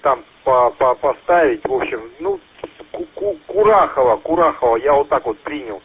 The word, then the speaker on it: Курахово: Правильно, город Курахово, ударение правильно делаешь, совершенно верно.